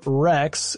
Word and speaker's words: RHex: There was also a robot called Rex, and that's spelled R-H-E-X.